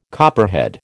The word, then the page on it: copperhead: American English